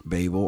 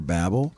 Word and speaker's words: Babel: to those that may prefer that pronunciation. I've researched it, and both are considered correct, Babel or Babel.